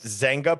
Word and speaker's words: Zynga: If you've not heard of, I believe I've pronounced that right, but Zynga. – So it's pronounced Zynga Poker.